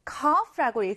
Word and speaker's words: cough: 이 기침은 cough, cough라고 읽습니다. C-O-U-G-H인데 F 발음이 난다는 점을 주의해 주세요.